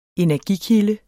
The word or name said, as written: energikilde